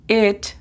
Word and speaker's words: it: It significa eso o esto. La pronunciación fonética es simplemente E-T, it.